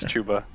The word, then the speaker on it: Chuba: I heard that name pronounced live, and I was really upset that it wasn't Chuba Hubbard. But yeah, it's Chuba.